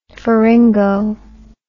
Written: pharyngo

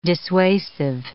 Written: dissuasive

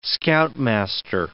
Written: scoutmaster

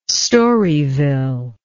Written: Storyville

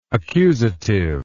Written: accusatively

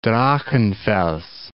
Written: Drachenfels

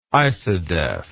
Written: isodef

